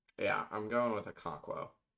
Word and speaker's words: Okonkwo: You said Okonkwo, right? – I believe so. Okay. That was a literary character in Things Fall Apart, a book we read in high school. So I think that's how you say it.